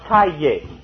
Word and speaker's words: calle: For example, those of you who know some Spanish, the word calle, C-A-L-L-E, is pronounced calle in Mexico and all the rest of the Spanish-speaking world. It means street. In Argentina, it's called calle. Notice the difference in the pronunciation.